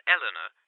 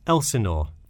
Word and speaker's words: Elsinore: I'm sorry, was that Eleanor? Could you spell that for me? – Sure. It's Elsinore. E L S I N O R E.